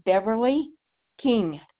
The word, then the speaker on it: Beverly King: Yes, it's Beverly King, K-I-N-G. That's spelled B-E-V-E-R-L-Y.